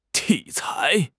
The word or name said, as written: 体裁